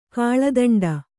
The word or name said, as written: ಕಾಳದಂಡ